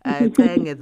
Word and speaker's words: Qenge: Amandla, you know, because she's Xhosa and she can pronounce Qenge the way, you know, it's pronounced in Xhosa.